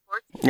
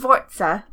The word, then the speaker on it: Forza: Okay, Google. How do you pronounce Forza? – That's pronounced Forza. – Yep. Forza. – What did it say? – I'm so fucking stupid. – It's Forza.